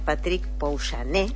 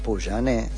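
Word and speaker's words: Pouyanné: Hubo una reunión a solas entre el titular de esta compañía francesa, espero pronunciarlo bien, Patrick Poujané. – Poujané, sí.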